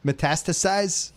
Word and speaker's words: metastasized: For the next couple years, that wedge metastasized. – Sure. – How do I say that word? – Yeah, that's right.